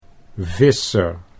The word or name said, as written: Visser